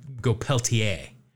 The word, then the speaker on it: Peltier: I like to go with the French pronunciation and go Peltier.